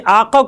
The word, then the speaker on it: آقا: جیسے آقا کو وہ لوگ کہتے ہیں آغا، یہ ہوتا ہے آقا لفظ قاف سے،